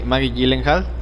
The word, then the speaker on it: Gyllenhaal: Maggie Gyllenhaal.